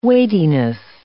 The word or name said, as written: weightiness